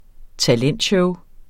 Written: talentshow

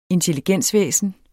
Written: intelligensvæsen

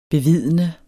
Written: bevidne

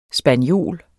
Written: spaniol

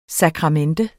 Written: SAKRAMENTET

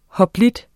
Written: hoplit

